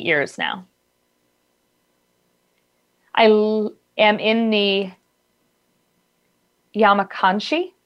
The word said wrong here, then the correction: Yamanashi: Yamanashi. – Apologies, the interpreter mispronounced. Yamanashi province near Tokyo.